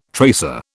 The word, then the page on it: tracer: British English